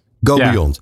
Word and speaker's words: Go Beyond: En dan nu een, een, campagne als Go Beyond, zeg ik het goed? Ja, Go Beyond.